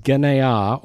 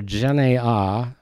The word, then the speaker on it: genea: The Greek word is genea, or genea, G-E-N-E-A, if you spell it in English.